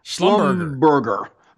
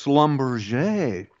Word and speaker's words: Schlumberger: Hat tip to Andrew, who figured out that it looks like a German word, and it is. But they come from the Alsatian region, which is why you get the French pronunciation. – Yes, Schlumberger. See, it ends in ER, so it would look like Schlumberger. – Right. – Schlumberger, but it's Schlumberger.